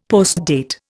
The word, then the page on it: postdate: Indian